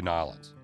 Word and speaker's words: New Orleans: No, but honestly, you said one of your bucket list things is to go to Nollins. – Yep. – You have to say it like that.